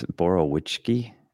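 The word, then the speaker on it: Borowiecki: And I always go with what we called him in the locker room, but clearly we've had mispronunciations in there. It's Borowiczki.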